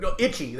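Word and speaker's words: Ichi: It wasn't Toichi. It wasn't pronounced right. Itchy the yes!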